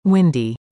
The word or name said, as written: Windy